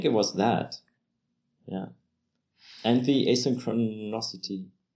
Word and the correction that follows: asynchronicity: How do you say it? – Asynchronicity, is it, I think? – Yeah.